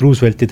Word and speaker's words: Roosevelt: President Roosevelti teemadel, ta vaatas selle Roosevelti hääldusse peale väga juhmi näoga otsa ja siis küsis, et do you mean Roosevelt? – Roosevelt. – Ja siis muigas, et kuidas poiss võib nii kündimatult hääldada,